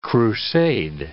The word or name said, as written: crusade